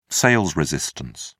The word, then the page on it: sales resistance: British English